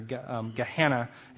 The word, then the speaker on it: Gehenna: Gehenna is how we pronounce it in English.